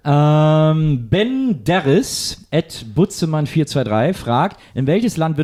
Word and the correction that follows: Benderis: Ich glaube, es ist Benderis. So spricht man es eher aus.